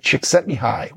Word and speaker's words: Csikszentmihalyi: As you can see from the title, it's Flow, The Psychology of Optimal Experience by Mihai Csikszentmihalyi, which is an impossible to spell. And that is the correct pronunciation.